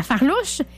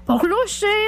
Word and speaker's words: farlouche: La tarte à la farlouche, qu'on prononce de bien des façons la farlouche, la pourlouche, ça dépend des régions.